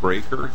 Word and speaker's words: Breaker: Yes, my name is Martin Breaker. That's spelled B-R-E-A-K-E-R, like jawbreaker.